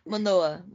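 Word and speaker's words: Manoah: É Manoá, acho que eu até pronunciei errado. – Manoá.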